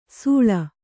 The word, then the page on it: ಸೂಳ: sūḷa